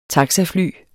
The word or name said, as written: taxafly